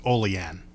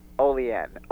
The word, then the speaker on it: Olean: Although, just for future reference, that's actually pronounced Olean. – Olean. Okay, thank you.